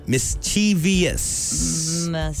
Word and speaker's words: mischievous: But that's pronouncing it wrong. It's just mischievous. – Really? – Yeah. – Mischievous. – It's three syllables, not four.